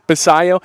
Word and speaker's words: Basallo: Baltimore Positive, I got a text message this morning that Basallo. – Basayo. – Okay, yeah, I want to say it right. Basayo.